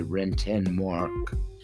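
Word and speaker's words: Rentenmark: potato, potato, whatever, it's spelled R E T E N M A R K. And I'm going to stand by Renten Mark and have at it.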